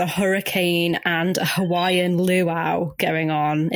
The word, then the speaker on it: luau: Yeah, I think you'll find it's pronounced luau. – Yeah, but it's spelt lua-ua. – But it's spoken luau. It's the Hawaiian pronunciation.